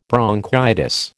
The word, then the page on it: bronchitis: American English